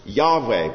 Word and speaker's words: Yahweh: It might have been pronounced Yahweh, but the Jews regarded it as such a holy word that they didn't even pronounce it.